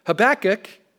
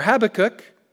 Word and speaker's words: Habakkuk: The prophet Habakkuk, or Habakkuk, or wherever you put the syllable,